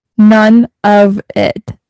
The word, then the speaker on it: Nunavut: Like, none of it. – Yeah, it's pronounced none of it, but it's spelled... – N-U-N-E-V-I-T.